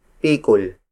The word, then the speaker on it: pikul: And the heaviest unit among native Tagalog measurements is pikul. Pikul. Pikul. Pikul is spelled as P-I-K-U-L.